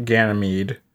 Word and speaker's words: Ganymede: Sweeney has been genetically altered to live on Ganymede, which is incredible. Is that not how you pronounce it? – I think you got it.